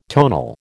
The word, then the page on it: tonal: American English